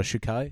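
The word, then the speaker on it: Czukay: The sad news about Holger Shukai.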